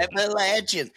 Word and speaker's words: Appalachian: Going back to kind of like that, you know, Appalachian, did I say it right? – Yes, yeah. You did. – I think you got it. – Nailed it. – Appalachian. Just think Appalachia coming at you.